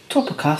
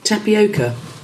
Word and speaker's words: tapioca: Topica starch. – Tapioca.